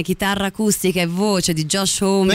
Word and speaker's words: Josh Homme: Esatto, è il 2005 e eh, i Queens of the Stone Age, eh, band di Josh Me, giusto? – Esatto.